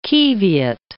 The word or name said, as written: qiviut